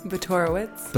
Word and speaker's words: Botorowicz: Botorowicz.